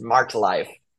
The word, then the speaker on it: Marked;Life: And so trust me, no one pronounces our name right to this day, but it's Marked semicolon Life. That's not how you pronounce it. It's just Marked Life.